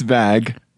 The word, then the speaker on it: VAG: Ian, it's Vag. VAG. What? – But it's spelled VAG.